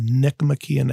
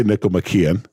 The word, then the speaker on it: Nicomachean: Say the word before Ethics, because I want to know how you pronounce it. – The Nicomachean Ethics. – Ooh, wow. Is okay. I mean, sure. I don't know. I say Nicomachean. – Nicomachean, Nicomachean, I've heard.